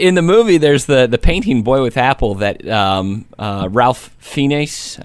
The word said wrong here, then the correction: Ralph Fiennes: I think I pronounced. – Actually, I believe it's pronounced Rafe Fiennes. – Rafe Fiennes.